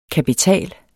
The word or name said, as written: kapital